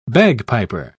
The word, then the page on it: bagpiper: British English